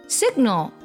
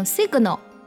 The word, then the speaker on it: signal: Signal 指的是信号的意思，读的时候 g 可以做一个不完全爆破，不用读成 signal，而是 signal。